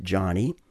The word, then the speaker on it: Joni: She spells her name J-O-N-I, but pronounces it Johnny.